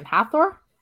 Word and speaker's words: Hathor: Hathor? – Yeah.